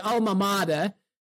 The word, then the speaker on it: alma mater: Talking about UNLV, your alma, I would pronounce it alma mater, but I'll try to do it in the American way. So your alma mater, home of the great International Gaming Institute.